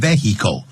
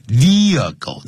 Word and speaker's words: vehicle: 不要念成 vehicle vehicle 那个 h 不发音的 Andrea 说了